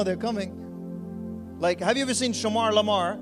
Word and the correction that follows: Shemar Moore: How do we say that uh, his name? Shemar Moore. Shemar Moore.